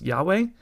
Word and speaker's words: Yahweh: It's commonly pronounced Yahweh, so we don't just have any God directing Jonah. This is Yahweh, the one who was and is to come. That's part of what his name means. He's Yahweh, the deliverer of Israel from Egypt.